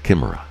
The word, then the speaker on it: chimera: something called chimeras, or is it chimera? How do you say that? Chimera, I think. – Chimeras. – Oh, chimera, long eye, that sound then, okay.